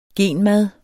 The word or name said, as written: genmad